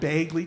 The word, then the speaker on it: Begley: How do you say the name of the actor Ed Begley Jr.? Beg, right? Ed Begley Jr., right? – Sure.